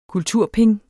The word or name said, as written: kulturping